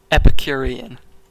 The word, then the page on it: epicurean: American English